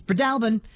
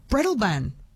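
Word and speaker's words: Breadalbane: Katrina in Roslyn says Fiona's correct. It's Bredalbin. Louise in Nowra says, Jess, I've only ever heard it as Bredalban.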